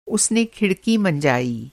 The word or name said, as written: मँजाना